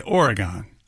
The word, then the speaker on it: Oregon: Think hexagon and then say Oregon. Same thing. – Oregon.